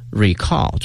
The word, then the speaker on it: recall: 所以呢大家记住了这个词 recall，注意一下它的发音，动词是 recall，终于后面放到了后面。